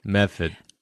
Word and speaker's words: Medford: There's no D in Medford. – Method. The pronunciations are very difficult in Massachusetts as a whole, and then Boston specifically.